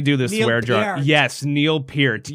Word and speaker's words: Neil Peart: You know, that's a hill that I'm gonna die on because I gotta respect that man's name by pronouncing it correctly, as do everybody else. So you know, it is Neil Peart, ladies and gentlemen. That is what it is.